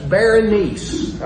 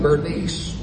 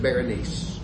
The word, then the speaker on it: Berenice: Now that's Berenice, I think is the right way to say that. It looks like Bernice, but when I checked it, I think they said Berenice.